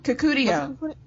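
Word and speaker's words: Kukudio: The fight between Suzanne and Kadikio, I think is pronounced. What was your takeaway? – Kakudio. Like Susudio? – Susudio? – That's right. Just like they made that reference, yeah.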